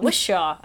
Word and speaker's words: Whishaw: It's like Pishaw. Wishaw. Okay.